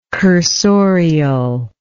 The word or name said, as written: cursorial